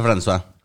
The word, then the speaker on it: François: Que se escribe Francois, pero creo que sí es este se pronuncia François.